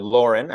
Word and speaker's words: Lauren: Ralph Lauren, or Lauren as it's pronounced here in the States,